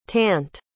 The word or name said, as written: tant